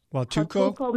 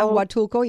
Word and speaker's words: Huatulco: Huatulco, Mexico. – Yeah, Huatulco, yeah.